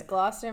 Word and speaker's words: Gloucester: Yes, Gloucester, Massachusetts.